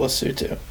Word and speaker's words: Lesotho: Point is, it's spelled Lesotho, but people over there would say it Lesotho.